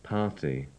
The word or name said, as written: party